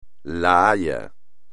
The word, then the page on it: La Haya: espagnol